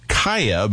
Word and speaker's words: Keiya: This artist who calls herself Kaya, but it's spelled small K-E-I-Y-A, capital A, blending neo-soul experimental R&B there for us with a track called Take It.